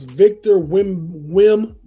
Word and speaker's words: Victor Wembanyama: I believe it's Victor Wembanyama. – That sounds correct.